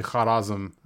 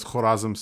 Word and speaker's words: Khorazm: Uh, and so uh, uh, the, they would say Kharazm, like like the word Khab or Dastarkhan or the like, like the word Khaja. whereas the locals in Uzbekistan, they, they seem to pronounce it as Khorazm, so I, I choose this pronunciation,